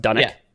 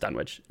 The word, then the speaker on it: Dunwich: So it's spelled Dunwich. Dunwich is a real town in Scotland, pronounced Dunwich. But it's set in a town in New England, so it may have been pronounced Dunwich. Lovecraft never specified which way it was supposed to be pronounced.